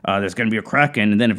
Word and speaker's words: Kraken: Kraken. – Kraken. – Kraken, yeah. So that's, like, that's not a long A. That's a soft Kraken encounter.